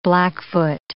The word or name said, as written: Blackfoot